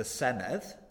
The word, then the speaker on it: Senedd: And soon to be referred to as the Senedd. – Right. So that's double D, but it's pronounced TH. Is that about right? – Sure.